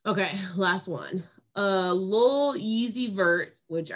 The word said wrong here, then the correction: Lil Uzi Vert: Lil Uzi Vert? – Yeah, okay.